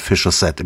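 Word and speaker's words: Fischer-Z: John Watts ist schon seit 42 Jahren mit seiner Band Fisher Z im Geschäft. Watts Kreativität lässt sich bereits am Bandnamen ablesen. Ein Wortspiel mit dem Ausdruck Fisher's Hat, Fischkopf, mit britischem Akzent ausgesprochen, ergibt sich klanglich Fisher Z.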